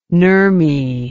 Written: nurmi

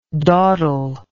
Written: dawdlingly

